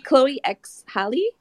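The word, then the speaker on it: Chloe x Halle: Chloe X Haley.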